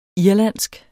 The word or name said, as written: irlandsk